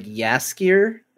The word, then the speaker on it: Jaskier: It's hard to say because it's like Jaskier, I believe, is how you pronounce it.